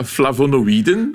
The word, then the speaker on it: flavonoïden: Eh, ja, flavonoïden.